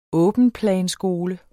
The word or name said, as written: åbenplanskole